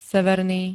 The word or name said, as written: severný